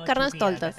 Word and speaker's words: Carnestoltes: ¿Cómo se dice? Carnestoltes. – No, Carnestoltes.